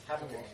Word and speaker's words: Habakkuk: Wait, why do you pronounce it Habakkuk? – That's more correct, technically. – See, but why though? – Yeah, because it's a Hebrew name.